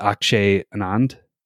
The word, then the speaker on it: Akshay Anand: but Akshay Anand, is that how you pronounce the name? – Sounds right to me, but cool.